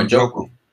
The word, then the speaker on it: Njoku: Oh, Njoku. – Njoku, ese es el cuat, ese miserable.